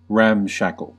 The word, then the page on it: ramshackle: American English; /ˈræmˌʃæk.əl/